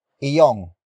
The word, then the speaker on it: iyo: It consists of two words, starting with iyong. Iyong. Iyong. Iyong is spelled as I-Y-O-N-G.